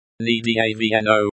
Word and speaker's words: недавно: The stress in. Недавно. Недавно is on the second syllable.